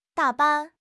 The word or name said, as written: da3 ban5